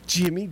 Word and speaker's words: Jyme: Because she spells it J-Y-M-E. And I was like, Jimmy. And we were trying to come up with nothing. We couldn't come up with. So when I called her, I said, how do you spell or say your name? Jimmy, just like yours.